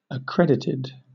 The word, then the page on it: accredited: British English